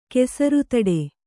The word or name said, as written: ಕೆಸರುತಡೆ